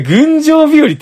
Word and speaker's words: 群青日和: あ、これ群青日和って読むんですね。